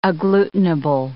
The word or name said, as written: agglutinable